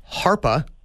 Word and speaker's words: HARPA: It's most likely, we're looking at it in China, and there's an organization called HARPA, rhymes with DARPA, right?